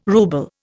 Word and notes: rouble: Indian

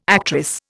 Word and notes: actress: Indian

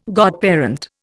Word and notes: godparent: Indian

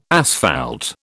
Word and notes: asphalt: British English

